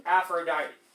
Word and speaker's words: Aphrodite: Hera, Athena, and Aphrodite. – Every syllable you pronounce with these Greek names, even if it doesn't look like it.